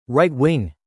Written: right-wing

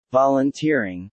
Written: Volunteering

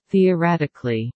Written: theoretically

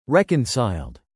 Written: reconciled

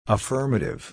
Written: affirmative

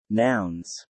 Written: Nouns

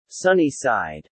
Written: sunny side

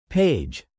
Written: page

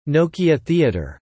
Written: Nokia Theatre

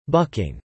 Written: bucking

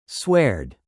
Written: sweared

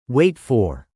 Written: wait for

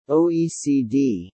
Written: OECD

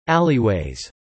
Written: alleyways

